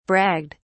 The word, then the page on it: bragged: British English